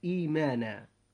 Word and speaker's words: إيمانا: وكذلك إيمانا، وأيضا كلمة إيمانا أصلها إيمانا، الهمزة الثانية الساكنة تبدل من جنس حركة ما قبلها، وجنس هذه الحركة هو الكسرة، وجنس الحركة التابع للكسرة هو الياء، فنقول إيمانا.